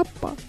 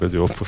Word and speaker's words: Oppo: Еще у вас по-английски, по-моему, «Об» читается ближе к букве «А», да? Тогда это будет какая-то «Оппа». Нет? – Нет, не здесь, вроде Oppo сам будет.